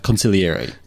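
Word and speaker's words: consigliere: I think you're not supposed to pronounce the G. It's just conciliary.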